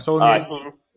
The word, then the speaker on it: Saulnier: And the suggestion of stomping its neck actually goes to, comes from director Jeremy Saulnier. Is that how you say his name? I don't actually know. – Saulnier.